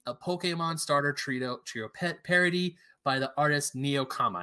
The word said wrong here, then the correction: Nekoama: I'm pretty sure I said that right. Or no, I was wrong. Nekoama. It's spelled Nekoama.